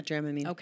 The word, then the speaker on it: Dramamine: Yeah, Dramamine.